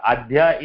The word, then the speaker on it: अद्य: अद्यः अहम् अद्य अद्यः when you say aha, it is pronounced as we have the visarga. There is no visarga. Adhya is means only two, today.